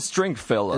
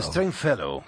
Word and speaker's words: Stringfellow: Ken Stringfellow. – El Stringfellow, perfecto.